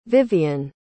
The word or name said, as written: vivien